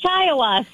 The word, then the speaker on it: Shiawassee: Really? Shawassee? That's how you pronounce that? I was... – It's Shiawassee.